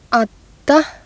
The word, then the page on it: adda: Icelandic